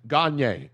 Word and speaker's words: Gagné: Yes, Gagné. – Gagné. It's pronounced Gagné. – Not Gangnam Style.